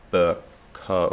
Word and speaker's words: Bercow: The Speaker of the House of Commons, John Bercow, I think you pronounce it, B-E-R-C-O-W, took a break from his family holiday to issue a statement saying he had not been consulted on the government's plan.